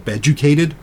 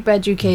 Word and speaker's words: Beducated: Not safe for work education sites are available to pick up the slack, including Be Educated, or maybe they call it Beducated. I don't know exactly how that's pronounced. – It looks like beducated. – Beducated, yeah, which, clever, okay, punny, which just launched its AI sex coach.